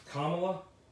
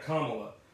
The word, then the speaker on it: Kamala: Now it's spelled the same, but it's pronounced Kamala. Kamala.